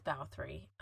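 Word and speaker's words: Báthory: Elizabeth Bathory, so her Elizabeth in Hungary is pronounced Elizabeth, and Bathory is how her last name is pronounced, and you know, for using the Hungarian dialect.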